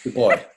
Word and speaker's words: Du Bois: Du Bois is writing, sorry, I did the French version of it, Du Bois.